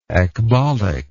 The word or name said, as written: ecbolic